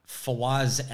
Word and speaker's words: Fawaz: So, yeah, Sean Fawaz, F-A-W-A-Z.